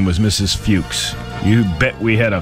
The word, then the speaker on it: Fuchs: It's Fuchs. – I believe it's Fuchs. – That's what people who have that name pronounce it as.